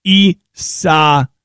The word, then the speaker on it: Isaias: The emphasis is on the third syllable. Isaias.